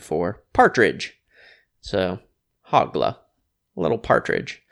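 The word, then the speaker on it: Hoglah: Interestingly enough, looks like the Hebrew pronunciation is Hogla. – Even better. – Yeah, a little Middle Eastern action. – Yeah. – Hogla. – That back of the throat action. – Hogla. Hogla. A little KH action.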